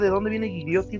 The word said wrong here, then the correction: Gigliotti: güey. – Gigliotti. ¿Cómo se escribe Gigliotti? ¿Así como se escucha? Gigliotti con doble T, ajá, Gigliotti. Sí.